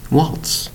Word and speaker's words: waltz: Waltz. One syllable. W-A-L-T-Z. And you'll notice the Z in this word sounds like an S.